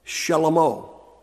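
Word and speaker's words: Shelomo (שלמה): Now, in Hebrew, his name is pronounced Shelomo.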